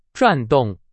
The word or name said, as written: 转动